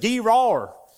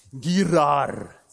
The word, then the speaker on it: Gerar: I went online, I said, because I got a southern accent, so my southern accent, Gerar. So I'm not saying it properly, it's Gerar.